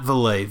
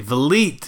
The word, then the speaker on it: Vliet: Not Valet. Vliet. Rhymes with meat.